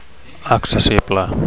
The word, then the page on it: accessible: Catalan